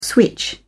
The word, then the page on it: switch: British English